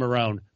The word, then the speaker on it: Marone: But DeFilippo and Marone for going for it on 4th down at the 4-yard line, up 20-7 with, less than 4 minutes to go.